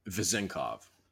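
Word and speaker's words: Vezenkov: Is it Vezinkov? Vezinkov? – Vezinkov. – Vezinkov. Okay, Vezinkov.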